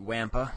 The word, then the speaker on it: Wampa: Which is 76588 WAMPA. rhymes with Tampa.